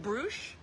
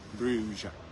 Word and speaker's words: Bruges: B-R-U-G-E-S. Bruges. – That's not Bruges? – No, the S is silent. A lot of letters in Dutch.